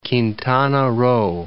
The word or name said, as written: Quintana Roo